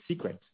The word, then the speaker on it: Seekret: Finally, this morning we announced that we acquired Secret, which is spelled S-E-E-K-R-E-T.